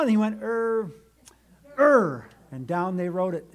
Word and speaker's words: Ur: So verse 3, she conceived and bore a son, and he called his name Ur. I don't think it should be pronounced error, but that's what it comes to be, sadly.